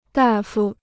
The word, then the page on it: 大夫: dà fū